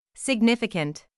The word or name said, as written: significant